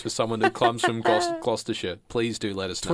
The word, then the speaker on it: Gloucestershire: Events of this episode is based on a real incident that took place in Stroud, Gloucestershire. – Gloucestershire. You're absolutely butchering the pronunciation of names here on this podcast, Connor.